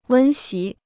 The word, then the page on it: 温习: wēn xí